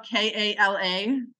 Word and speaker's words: Kala: So my name is actually spelled Kala, but I get the infamous Kayla all the time.